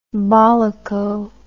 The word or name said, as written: bollocko